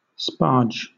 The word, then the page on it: sparge: British English